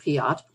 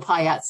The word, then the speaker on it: Piatt: And I never really enjoyed that debate, but we were often referred to as snobbish for using the pronunciation of Piatt. And my father would retort that that was the French pronunciation and that the family came from France. So in his mind, that was the correct pronunciation.